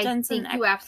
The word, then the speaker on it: Jensen Ackles: And, of course, that's the one that everybody absolutely loved because it has Jensen Ackles in it. Did I say that right? – Jensen Ackles.